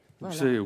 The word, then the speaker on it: Oulx Est: Ça s'écrit O-U-L-X-E-S-T. Alors, ça se prononce, je ne sais pas, Ouxest, Ouxé. Comment on dit dans le coin ? – Je ne sais pas encore. Très bien. – Ouxé. – Ouxé, Ouxé, oui.